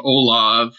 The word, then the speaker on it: Olav: Yeah, I have a website, just my first name, Bruce, middle name, Olav, that's spelled O-L-A-V, last name, Solheim, S-O-L-H-E-I-M, as in Michael.